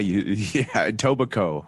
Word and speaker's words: Etobicoke: It's Etobicoke? – Yeah, Etobicoke. – Oh. – You don't spell it Coke.